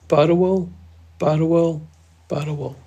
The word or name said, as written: badhawal